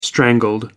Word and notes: strangled: American English

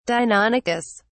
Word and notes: deinonychus: British English

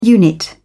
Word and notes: unit: British English

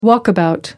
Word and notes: walkabout: American English

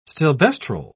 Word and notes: stilboestrol: British English

